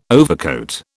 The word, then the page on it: overcoat: British English